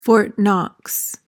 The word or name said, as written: Fort Knox